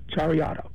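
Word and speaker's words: charreada: So now let's just divert a little bit into how do you pronounce it, Chariada? – That's close. Chariada.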